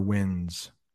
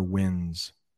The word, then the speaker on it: winds: And I'm not sure if you've noticed, but when I pronounce wind, a lot of times I will leave off that D, especially when I say it in the plural, stronger winds. I don't pronounce that D, stronger winds. Why? I don't know, but it is hard to say winds. So native English speakers will probably drop that D, stronger winds.